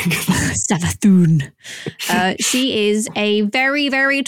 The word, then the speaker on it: Savathun: And they have revealed the Witch Queen Savathun, which I don't, there is an accent on the U, maybe it's Savathun. I don't know. – I think it's Savathun.